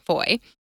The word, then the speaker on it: foi: And foy is French, F-O-I is how it's spelled, but it's pronounced similarly, foy.